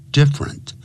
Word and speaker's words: different: different不要念成 different 不是三个音节、啊、是两个音节 different different different 这样念